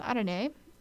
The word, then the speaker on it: arare: It's not arari. Cringe. But yeah, it's pronounced arare.